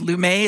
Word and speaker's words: Lumet: I think either's fine because Lumet is just like the French version.